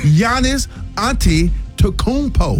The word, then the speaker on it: Giannis Antetokounmpo: Well, the Milwaukee Bucks, they are back in the strong consideration of trading Giannis Antetokounmpo. – No. – Did I say it right? – Did he say it? – Let me see. Giannis Antetokounmpo.